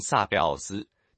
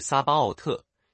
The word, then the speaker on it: Sabaoth: 又比如万军之耶和华 Lord Sabaoth 这个名字，很多人念成撒贝奥斯，其实是撒巴奥特，意思完全不同，是万军之主。